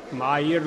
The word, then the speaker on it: Mayr: Acest bărbat, care se numește Ernst Mayr, Mayr, nu știu cum îl pronunță americanii, însă el este german și eu îl pronunț german,